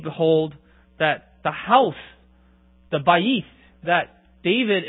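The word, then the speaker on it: bayit (בית): Now, in verse 5, the Hebrew word for a house is, now, if I remember correctly, my Hebrew is a little choppy, but it's pronounced bayit.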